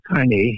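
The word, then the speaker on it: Cane: Yeah, it's a C-A-N-E, pronounced carne, mm-hmm. and corso, C-O-R-S-O.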